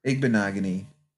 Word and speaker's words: Igbinoghene: Igbinoghene.